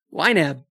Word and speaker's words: YNAB: YNAB.